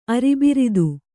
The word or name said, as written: ಅರಿಬಿರಿದು